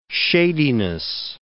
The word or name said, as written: shadiness